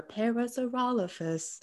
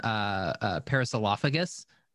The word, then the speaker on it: Parasaurolophus: I was pronouncing them wrong since I was a kid. I've been pronouncing them Parasilophagus this entire time.